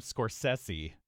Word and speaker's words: Scorsese: But I believe he does pronounce his name Scorsese.